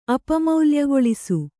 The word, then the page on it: ಅಪಮೌಲ್ಯಗೊಳಿಸು: Kannada